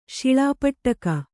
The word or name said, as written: ಶಿಳಾಪಟ್ಟಕ